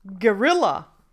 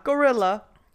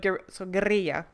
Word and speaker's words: guerrilla: And the word is guerrilla. Not gorilla, but guerrilla. – Guerrilla.